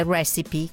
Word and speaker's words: recipe: La prima parola che ci interessa è recipe, che significa appunto ricetta, in senso stretto o figurato. Come molte altre parole inglesi che finiscono in e, richiedono che la vocale si legga.